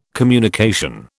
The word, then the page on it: communication: British English